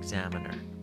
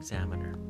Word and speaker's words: Examiner: کمی صحیح تر این کلمه در انگلیسی به این شکل تلفظ میشه این اسم مذارت میخوام واشنگتن Examiner واشنگتن Examiner